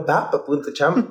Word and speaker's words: Pápa Chama: Arroba papa, punto, le digo, arroba papa punto chama, punto chama, este, con acento en la primera A.